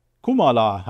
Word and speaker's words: Kamala: Kamala Harris, Kamala, hat Martin eben noch gesagt, wäre eigentlich die richtige Aussprache, hätte sie jetzt letztens erklärt. Kamala, egal, auf jeden Fall, Kamala sage ich eigentlich lieber, das geht irgendwie besser über die Zunge, wie auch immer, das werden wir uns bestimmt alle jetzt mal angewöhnen irgendwie.